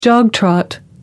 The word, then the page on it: jog trot: American English